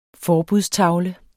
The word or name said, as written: forbudstavle